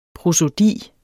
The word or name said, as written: prosodi